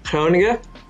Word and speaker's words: Groningen: It's actually pronounced Groningen by Dutch, but I'm not going to do that to you.